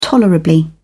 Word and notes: tolerably: British English

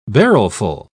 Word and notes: barrelful: British English

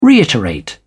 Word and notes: reiterate: British English

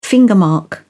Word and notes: fingermark: British English

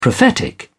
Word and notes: prophetic: British English

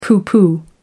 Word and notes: pooh pooh: American English

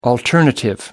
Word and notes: alternative: American English

